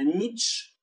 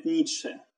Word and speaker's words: Ницше: Если лет 50 назад, судя по текстам, судя по свидетельствам, а, французы не, не могли произнести фамилию Ницше, а говорили исключительно Нич, потому что конечное «э» не читается, а, то сейчас француз говорит Ницше.